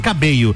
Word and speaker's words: Cabello: cabelo, a pronúncia correta, né? Camila Cabelo. Cabello.